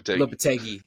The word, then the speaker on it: Lopetegui: I keep getting his name wrong all the time. – Lopetegui. – There you go.